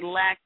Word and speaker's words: Lakin: I'm going to say Lack, L-A-K-I-N, at Lackins.